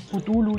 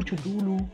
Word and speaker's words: Cthulhu: Nunca lo Tiene muchas pronunciaciones. Cthulhu, Chutulu, Cthulhu.